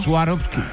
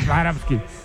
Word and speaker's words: Swarovski: Como Swarovski.